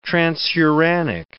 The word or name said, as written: transuranic